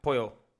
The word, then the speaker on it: pollo: Salsa de polla. Salsa de polla is dick sauce. – How do you say Kentucky Fried Chicken in Spanish? – Kentucky Fria Polla. Pollo. Not polla.